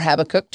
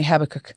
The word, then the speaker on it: Habakkuk: But going back to Habakkuk three or Habakkuk, depending on how you pronounce it. – Um, my daddy taught me Habakkuk.